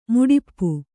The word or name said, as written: ಮಡಿಪು